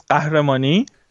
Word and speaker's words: Qahramani: My surname is Qahramani. – Which there's no relation between the spelling and pronunciation. – GH, we pronounce it as Gh, yes.